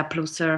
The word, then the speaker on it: Plusser: So you have to follow me on my virtual connection sites like website or Facebook, Miranda Plusser Facebook or Misoko Facebook page or 6IX Misoko website. Yeah, so and then a Plusser is spelled like PLUSSER.